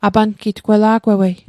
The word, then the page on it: apangitg: apankitk; Mi'gmaq